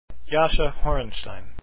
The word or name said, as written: horenstein